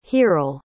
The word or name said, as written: hiral